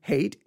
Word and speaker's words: Haidt: At the same time, this moral psychologist, again, Jonathan Haidt, H-A-I-D-T, and I keep trying to figure out exactly how to say it because I don't want to say hate like H-A-T-E, but it's H-A-I-D-T.